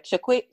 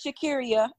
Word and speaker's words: Shakira: You know, we had to spell out that pronunciation because we knew somebody was going to read it and be like, Shaquit, Shaquit, you know, pronounce. So we're like, yeah, it's Shakira.